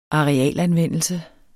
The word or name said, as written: arealanvendelse